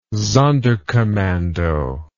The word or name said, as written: sonderkommando